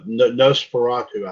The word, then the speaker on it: Nosferatu: Nostrado. – That's close enough. Nosferatu, I think is how it's pronounced, but yeah, that's close enough.